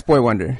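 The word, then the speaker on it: Boi-1da: Because it's spelled B-O-I dash the number one D-A. And I was like, oh, man, I'm out of touch. I don't know how to pronounce. I had to go on YouTube to find a Boy Wonder video to find out, oh, yeah, that's Boy Wonder.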